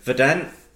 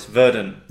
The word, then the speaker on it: Verdant: While we're in North London, there was news that Pressure Drop are joining forces with Verdant. I've said that wrong, haven't I? It's Verdant.